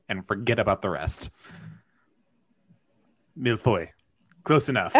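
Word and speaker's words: mille-feuille: So I do have to say that the milifuili is pronounced milfoy.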